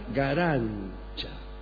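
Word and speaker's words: Garanča: L'amour est ram, no, l'amour est ram, no, bien, y hay una cantante que nació en Letonia que se llama Elina Garancha. Se escribe Garanca, pero se pronuncia, para su desgracia, Garancha.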